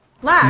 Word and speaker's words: Lac: Wait, is it Locke or Lack? – Lack.